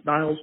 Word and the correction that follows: Nels: Jensen. I know I'm pronouncing it horribly, his name is horribly, but he's done work with Sugar Rose and also Jamie Foxx. What was it like working with him? – Yes, Nels Jensen, but you're very close, brother, very close.